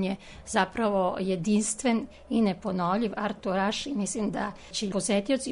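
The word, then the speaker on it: Arthur H: To u stvari, on se zove Artur Ižlen, ali na francuskom se to izgovara Artur Aš, odnosno Artur H.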